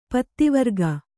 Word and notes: ಪತ್ತಿವರ್ಗ: Kannada

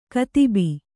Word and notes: ಕತಿಬಿ: katibi

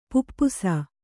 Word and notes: ಪಿಪಾಸೆ: kannada

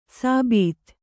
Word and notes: ಸಾಬೀತ್: sābīt

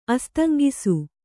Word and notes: ಅಸ್ತಂಗಿಸು: Kannada

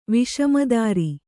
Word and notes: ವಿಷಮದಾರಿ: Kannada

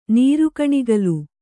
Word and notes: ನೀರುಗಣಿಗಲು: nīrugaṇigalu

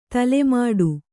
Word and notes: ತಲೆಮುಡಿ: tale muḍi

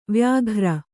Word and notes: ವೈಯಾಘ್ರ: kannada

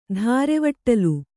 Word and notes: ದಾರೆವಟ್ಟಲ್: dārevaṭṭal

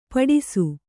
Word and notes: ಪಟ್ಟಿಸ: Kannada

